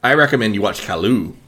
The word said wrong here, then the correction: Caillou: You can't watch Caillou. Caillou's a little fucking... – Oh, is that how you say it? Caillou? – What did you say? Kalu? – What?